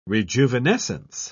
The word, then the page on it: rejuvenescence: British English